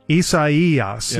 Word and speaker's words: Isaias: Isaias, yeah, is the way you pronounce it, is threatening Tuscaloosa on Saturday, a 100% chance of rain, winds 20 to 30 miles an hour.